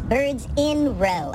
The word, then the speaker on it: Birds in Row: I've been calling them birds in a row for, Jesus, 10 whatever years now. I'm just now, in researching this episode, learned that I've been pronouncing their name wrong the whole time.